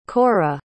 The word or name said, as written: korah